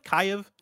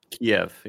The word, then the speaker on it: Kyiv: Is it Kyiv? Kyiv? – Kyiv, yeah. – Kyiv. – Kyiv, you got it.